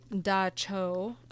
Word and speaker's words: dab tsog: Almost all the interviewees were familiar with the Da Cho, spelled Dab Tsog.